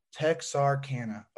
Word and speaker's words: Texarkana: So being in is it Texarkana? Is that how I pronounce it? – Texarkana.